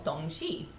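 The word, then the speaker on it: Dongzhi: I am going to talk about a holiday called Dongxi. I really don't know if I've pronounced that properly. I'm pretty sure that one is correct.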